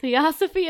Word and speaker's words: Theosophy: Theosophy? – Yeah, that's what it looks like. – Theosophy and biblical prophecy in March of 1972.